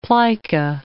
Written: plica

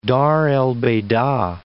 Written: Dar el Beida

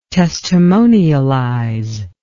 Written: testimonialize

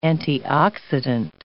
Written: antioxidant

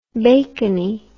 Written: bacony

